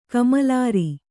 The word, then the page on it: ಕಮ್ಮಲರ್: kammalar